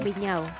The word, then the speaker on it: Viñao: Espero haber dicho bien el apellido porque es... Eh, se escribe Big Now, pero le vamos a preguntar a ella si lo dije Viñao. – Viñao.